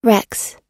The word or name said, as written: rex